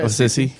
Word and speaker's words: Assisi: But the AI insisted it was a real priest living in Assisi, Italy. Am I pronouncing that correctly? Probably not. – No, you are. St. Francis of Assisi. – Okay. – Assisi. I've heard of it both ways.